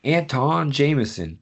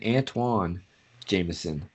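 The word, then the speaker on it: Antawn Jamison: I always pronounce this guy's name differently than it's spelled, so I'm going to pronounce it the way it's spelled, and then I'll pronounce it the way I've always pronounced it. But it's Anton Jameson, but I've always pronounced it Antoine Jameson.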